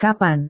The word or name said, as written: kapan